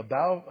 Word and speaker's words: bough: And Abimelech took an axe in his hand and cut down a, how do you say that word? A bough. Okay, a bough. I was going to say it wrong, so...